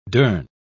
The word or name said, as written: durn